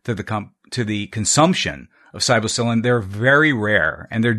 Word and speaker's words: psilocybin: Psilocybin? Mm-hmm. Am I pronouncing that correctly? – Psilocybin. – Psilocybin.